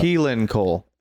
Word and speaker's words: Keelan Cole: Keenan Cole? Who's that? – Keelan Cole.